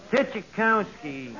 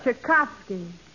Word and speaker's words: Tchaikovsky: Tchaikovsky. – That's Tchaikovsky.